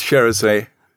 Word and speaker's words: Charisse: I was going to say, everybody gets it wrong with Charisse. – I know, Charisse. – It's Charisse Hushieri.